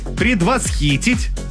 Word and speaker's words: предвосхитить: Как правильно произносить? Итак, говорите «предвосхитить».